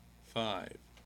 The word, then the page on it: five: American English